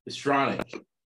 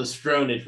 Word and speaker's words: Stronach: Now I know how to pronounce her last name. Stronach. Belinda Stronach. You spelled the Stronach, but I kept calling him that.